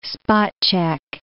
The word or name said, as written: spot-check